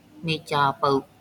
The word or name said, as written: မိစဴဗု